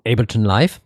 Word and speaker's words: Ableton Live: On a tous les stations de travail du numérique de la mort, et en particulier quand on a Ableton Live ou Ableton Live pour les gens qui le prononcent un peu à l'américaine.